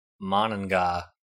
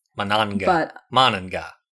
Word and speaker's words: Monongah: What did you say? How did you say it? – Monongah. – Yeah, I guess so. Must be in West Virginia. – Monongah.